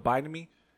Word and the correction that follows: Bimini: No, the B-I-M-I-N-I. – The Bimini Road.